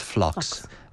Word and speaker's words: Phlox: So that's Phlox. So the P is silent. – Okay. Yes, like pharmacy.